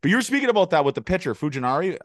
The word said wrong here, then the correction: Fujinami: How do you pronounce it? – Fujinami.